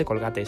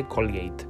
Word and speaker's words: Colgate: Pero ella dice que no se dice Colgate, se dice Colgate.